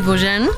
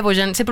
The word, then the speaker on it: Beaujean: Se escribe Beaujean con J. – Boyan, pero se pronuncia. – Se pronuncia Boyan, pero... – ¿De dónde es? ¿De dónde? – ¿Francés? – Es francés.